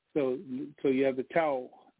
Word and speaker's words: Tao: One of the images that popped in my mind right away is the Tao, and that Tao is spelled T-A-O. It's pronounced Tao, most when you hear Tao, and it has that yin and yang symbol.